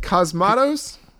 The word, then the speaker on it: Cosmatos: How do you say it? Cosmatos? – That's right.